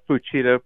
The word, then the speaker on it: Fuchida: Fuchida, probably. Okay. Mitsuo Fuchida is the way we say it in English.